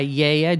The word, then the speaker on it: Gaia: N-O-T-I-O-S. – And it's made by Yaya, G-A-I-A. They don't pronounce the G.